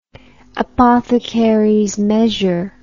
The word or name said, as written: apothecaries measure